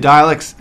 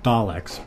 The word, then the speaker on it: Daleks: So where does the Daleks fit into the pantheon of Doctor Who foes? – Well, I think it's actually Daleks. But, oh, yeah, sorry. – But there's many acceptable pronunciations.